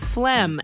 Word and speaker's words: phlegm: Phlegm. And it's not... what's weirder is to see it written because it's spelled P-H-L-E-G-M. It's like phlegm.